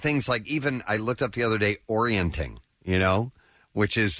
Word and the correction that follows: orienteering: Orienteering, or is that how you say it? Orienteering. – Yeah.